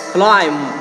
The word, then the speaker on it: climb: 首先你 CLI 它我给大家一个就是 climb，注意后面的 B 不发音 climb，然后呢后面的 max。